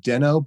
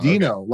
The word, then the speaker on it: Deno: So, you know, some people pronounce it Deno, but they're wrong because they're it's wrong. Dino, okay. Like the dinosaur.